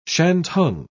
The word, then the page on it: shantung: British English